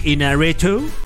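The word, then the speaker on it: Iñárritu: Inarritu.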